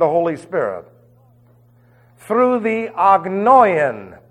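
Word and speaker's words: Agnoian (ἄγνοιαν): Through the agnoian, I'll spell that for you, A-G. It's really not a G, but I'll say it's a G. N-O-I-A-N. Agnoian.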